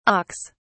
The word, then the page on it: auks: British English